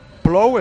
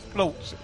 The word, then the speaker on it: plou: Plou es llueve. Plou. Sé que suena raro y que no se parece, pero... – ¿Cómo has plou. – Dicho? Plou. – P-L-O-U.